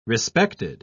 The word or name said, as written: respected